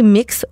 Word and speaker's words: Mx: C'est une lettre qui est assez longue où on invite les élèves à privilégier le titre de civilité MX, qui est prononcé mix au lieu de monsieur, madame, lorsqu'ils vont s'adresser à leur enseignante.